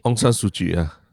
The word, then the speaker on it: Aung San Suu Kyi: Aung San Suu Kyi.